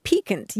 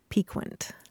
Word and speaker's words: piquant: I mean, we were talking about the word piquant. You mm-hmm. used it a couple of weeks ago. And I had always heard it pronounced piquant. – And so here at this point of your life, you're ready to make the change to the proper pronunciation? – Well, I did find that in one dictionary, the Merriam-Webster, it's the third pronunciation they offer.